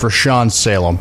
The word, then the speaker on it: Rashawn Salaam: By the way, it's Rashawn Salaam.